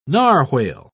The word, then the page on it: narwhale: British English